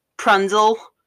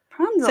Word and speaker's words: Rapunzel: she pronounces Rapunzel Prunzel Rapunzel.